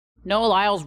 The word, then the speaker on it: Lyles: Noah Lyles rhymes with Simone Biles